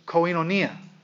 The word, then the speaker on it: koinonia: The Greek word for fellowship is koinonia.